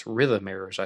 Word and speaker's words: Rhythmaires: R-H-Y-T-H-M-A-I-R-E-S, Rhythmers, I think that would be the best way to pronounce it.